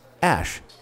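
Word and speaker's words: Æ: Actually, he told Joe Rogan, it's just X, the letter X, and then A-E, pronounced Ash.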